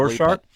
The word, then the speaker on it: Rorschach: I don't know if I'm saying Rorschach right. I've said it three times incorrectly now, probably. – Rorschach, I believe, is how it's pronounced, but you're close. It's fine.